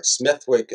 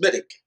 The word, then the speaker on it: Smithwick: The only place in the world where Smithwick is not pronounced Smithwick is in Ireland, where it came from, and there it's pronounced Smittick, like Smittick Ale.